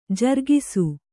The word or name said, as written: ಜರಗಿಸು